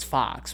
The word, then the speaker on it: Fawkes: Some some of my closer friends are going to know this, but uh his name is Mr. Fox well, it's Fox, but it's spelled F A W K E S. Fox.